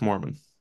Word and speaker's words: Moerman: It's Mormon. – Mormon. Ryan Mormon. It's spelled Moerman. For those listening via audio, which is Correct. – everybody. – Not like the religious sect that attends BYU.